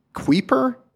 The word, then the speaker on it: Kuiper: It's K-U-I-P-E-R. Kuiper? – Kuiper.